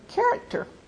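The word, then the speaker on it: character (χαρακτήρ): But the actual Greek word, I think I put it in your scripture references, is the word character. It's pronounced like the character.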